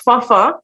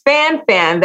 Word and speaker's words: Fanfan: It's actually of Haitian descent. It's pronounced Fafa. Mm-hmm. But of course, you know, in our English language, it is pronounced Fanfan. That's what people get. That's how they are able to pronounce it.